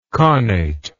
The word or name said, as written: connation